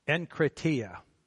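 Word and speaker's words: enkrateia: The Greek word for self-control in this particular passage is enkratia. Enkratia. Enkratia. That's how you say it.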